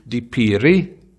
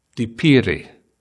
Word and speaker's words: dipiri: and if you get the tone wrong, the word is completely different. For example, if I said to you, dipiri, dipiri, spelt the same, basically pronounced the same, but means two different things.